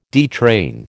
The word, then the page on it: detrain: American English